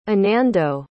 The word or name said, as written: anando